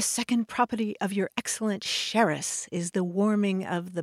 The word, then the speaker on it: Xeres: And that city's name was originally spelled X-E-R-E-S. And in the olden days, it had an S-H sound at the beginning of it. And the name of this beverage was adapted into English as Xeres.